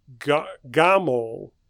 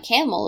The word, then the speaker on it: gamol: Oh, uh, gamol, which means old. – Not camel, apparently. – Not not gamal, but gamol.